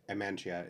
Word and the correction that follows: Amanita: it's a mushroom. – Amanita. – Amanita, thank you.